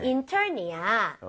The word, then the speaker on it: intern: I-N-T-E-R-N. 아까 전에는 그냥 넘어갔지만 얘는 발음을 꼭 건드려야 돼요. 중간에 R 있기 때문에 그냥 intern은 안 되겠죠? 혀가 뒤로 당겨져서 R 사운드를 낼 기회는 줘야 되는 거 아니에요? 그렇죠? 그렇기 때문에 inter 하고 R을 많이 당겨주시고 그 다음에 응 해주는 겁니다.